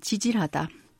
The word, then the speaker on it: 지질하다: La plupart des Sud-Coréens prononcent ce mot Tijirada comme era le fait, mais sa prononciation correcte est Tijirada.